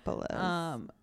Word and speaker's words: Yiannopoulos: Yiannopoulos. Milo. – Yiannopoulos.